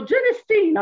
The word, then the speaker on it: genistein: I learned how to pronounce genistein. I always said genistein. Yes. Nobody ever told me. So genistein, I will try to remember that.